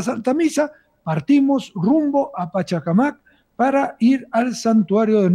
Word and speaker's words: Pachacamac: ¿Esto es cómo se pronuncia? ¿Pachacamac o Pachacamac? – Sí. Sí, Pachacamac, ¿no? Así es.